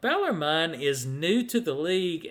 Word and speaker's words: Bellarmine: Well, there's a team that was new to their conference, and I don't even know if I'm pronouncing this right. Bellarmine is the way it's pronounced.